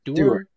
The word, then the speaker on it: Doerr: Doerr. – Mason Doerr, like, because you're a Doerr.